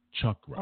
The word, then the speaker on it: Chakra: Now, I must ask you again, the series, and I've tried to make sure that I said, make sure I'm pronouncing this in the correct manner. Chakra. Uh-huh. Is that correct? Yes, Lord. The Chakra series.